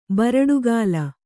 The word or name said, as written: ಬರಡುಗಾಲ